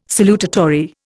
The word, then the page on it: salutatory: Indian